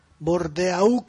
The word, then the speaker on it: Bordeaux: Vabbè, O è l'acqua, però non si scrive Bordeaux, è una città, Bordeaux, la X finale non si pronuncia.